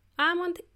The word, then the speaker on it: almond: It is spelled as A-L-M-O-N-D, and there is a silent L. So my friend didn't know there was a silent L in there. And when you say it, you kind of prolong that almond.